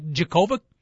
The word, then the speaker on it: Djokovic: And we should also mention the guy he beat, Big Dog, Novak Djokovic, with the silent D. It's pronounced Djokovic, but there's a D in there somewhere.